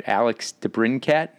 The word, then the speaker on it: Alex DeBrincat: Did you know, and I'm not sure if I'll get his name right, Alex DeBrincat? – Yes. And you did say his name right.